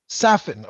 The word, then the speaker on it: Safin: How do you pr pronounce it? Safin, Safin, or Safin? – Safin. Okay.